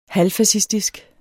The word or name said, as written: halvfascistisk